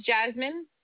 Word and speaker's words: Jasmin: So my name is Jasmine, J-A-S-M-I-N.